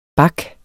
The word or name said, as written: BAKS